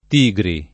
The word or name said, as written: Tigri